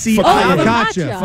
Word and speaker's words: focaccia: F-O-C-A-C-C-I. – Focaccia.